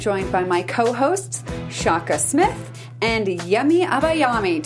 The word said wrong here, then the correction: Abayomi: Did I say that right? – Close. – Dang it. – Abayomi. – Abayomi. Gosh, I love the way it rolls off the tongue when I say it correctly.